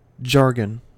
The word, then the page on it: jargon: American English